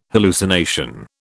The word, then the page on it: hallucination: British English